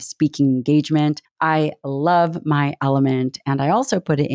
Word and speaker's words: LMNT: It's spelled L-M-N-T, and it's pronounced Element.